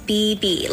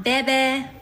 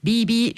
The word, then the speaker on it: Bebe: My name is Bibi, like two B's, not Bebe, Bibi. – Ja, also den Nachnamen dieser Bibi spricht man zum Glück einfach so aus, wie man ihn schreibt.